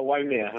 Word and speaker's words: Waimea: Uh, Chef joined Mauna Lani Bay Canoe House in 2012 from his namesake restaurant in Waimea. Am I saying that right? yeah, uh, Waimea. – Waimea, Waimea.